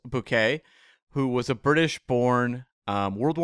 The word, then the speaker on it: Bucket: And she pronounces it Bouquet, and it's like written Bucket.